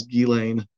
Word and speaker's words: Ghislaine: But I'm positive the correct French pronunciation is Ghislaine.